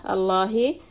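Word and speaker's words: Allahi (اللهِ): And the lam in the lafzi jalala, Allahi, is going to be pronounced la. It's heavy.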